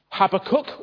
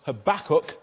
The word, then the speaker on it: Habakkuk: Habakkuk, or you might sometimes hear his name pronounced Habakkuk, but I was brought up with Habakkuk, so that's what I'm sticking with.